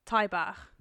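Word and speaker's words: Tai bach: So, but the house that he painted it on was in a neighbourhood called Tybach, which in Welsh means toilets. – Tybach or Tybach? – Tybach. – Which, how do you spell that? – T-A-I. – Bach. T-A-I. Bach. – So Tybach. So it also means little house. I think it's T-A-Y, Bach.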